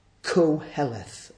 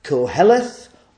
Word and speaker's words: Koheleth: Translated from Hebrew to Greek, this is the name Koheleth, and that is exactly how it's pronounced. Koheleth or Kohelet. You can decide which one you prefer.